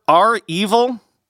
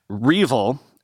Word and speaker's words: REvil: Remember, REvil that big ransomware gang, by the way, I've learned it's pronounced R evil, not Revel, as I was saying.